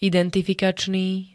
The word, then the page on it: identifikačný: [-d-t-]